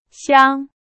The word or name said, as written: 箱